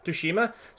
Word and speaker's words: Tsushima: Ghost of Tushami for the PlayStation Tsushima. Tsushima. So I started. Pl- it's a hard. It's really hard to say if you're not used to making that sort of sound.